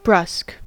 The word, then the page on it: brusque: American English